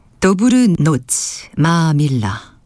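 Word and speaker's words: Dobrú noc, má milá: 그 중에서 도브르 노츠 마밀라, 이게 체코로는 이렇게 발음한다고 하네요.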